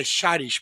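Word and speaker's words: Šarić: Na verdade, a pronúncia dele é Saric. Ele, ele fala que é, é Charis, porque tem o Szinho com o acento deles lá, uma outra língua. É, então, é, pra gente é Saric, né?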